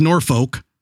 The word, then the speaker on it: Norfolk: It's spelled G-R-E-E-N-W-I-C-H. – Yep, Greenwich. – No W at all. – Yep, you've got Greenwich, you've got Norfolk. Norfolk's not too far away, which is Norfolk.